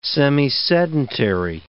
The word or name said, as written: semisedentary